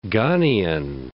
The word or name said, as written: Ghanian